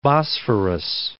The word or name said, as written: Bosphorus